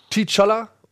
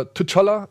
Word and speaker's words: T'Challa: ähm, ist angesiedelt nach Civil War Challa, T'Challa oder T'Challa, wie er zigfach äh, verschieden im Film ausgesprochen wird,